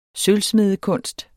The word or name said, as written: sølvsmedekunst